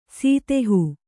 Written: ಸದೇಹ

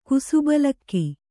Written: ಕುಸುಬಲಕ್ಕಿ

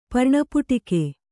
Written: ಪರ್ಣಪುಟಿಕೆ

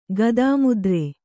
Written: ಗದಾಮುದ್ರೆ

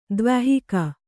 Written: ದ್ವ್ಯಾಹಿಕ

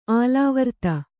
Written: ಆಲಾವರ್ತ

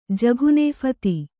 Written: ಜಘುನೇಫತಿ

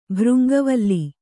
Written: ಭೃಂಗವಲ್ಲಿ

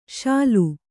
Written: ಶೂಲಿ

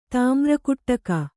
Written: ತಾಮ್ರಕುಟ್ಟಕ

